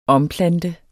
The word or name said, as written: omplante